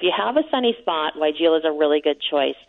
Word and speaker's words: Weigela: So people don't know how to pronounce that plant, but it is Weigela.